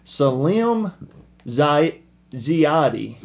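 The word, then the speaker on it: Salim Ziady: Salim Ziadi. – Yeah. – S-A-L-I-M is first name. Last name Z-I-A-D-Y.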